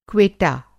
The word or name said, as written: क्वेटा